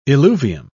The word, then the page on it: eluvium: British English